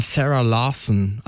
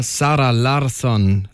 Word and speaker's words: Sarah Larsson: Alle sagen mal Sarah Larsson, aber es ist eine Schwedin, also eher Sarah Larsson, Lush Life.